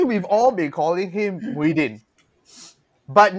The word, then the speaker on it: Muhyiddin: And then for official documents, his actual name is Mahiaddin. So it's Muhyiddin. – Muhyiddin. – Yeah. From what we know, that's how you pronounce it, lah.